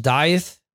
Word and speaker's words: Dieth: The subjects covered include new tracks by Skid Row, Disturbed, Arch Enemy, and David Ellefson's new death metal band, Dieth. I guess that's how that's pronounced. D-I-E-T-H, Dieth, maybe.